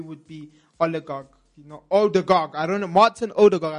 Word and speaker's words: Odegaard: I hope I say his name right. Oligarch. – Oligarch. – No, I'm saying his name so wrong. I'm butchering his name. I'm so sorry. Odegaard.